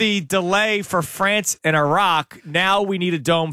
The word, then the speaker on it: Iraq: It's Iraq. – Iraq.